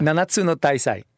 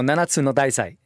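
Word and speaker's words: Nanatsu no Taizai: de Nanatsu no Taisai,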